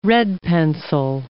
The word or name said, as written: red-pencil